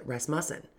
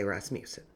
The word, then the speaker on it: Rasmussen: And I'm going to stop right there because I know people with this last name, and in Indiana, they pronounce it Rasmussen. But everything I listened to and looked at said Rasmussen, so I will say Rasmussen.